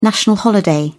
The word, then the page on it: national holiday: British English